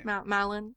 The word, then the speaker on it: Malon: Melon or Melon? – Melon. – I see. Yeah, I don't know how to say her name. – Malon. Malon.